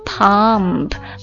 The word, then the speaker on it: pomp: 第三个单词读作 pump, pump, P-O-M-P, pump, pump, pump, 不 P 开头 P 结尾 pump, 名词表示排场或者盛况浮华壮丽夸耀等等意思啊。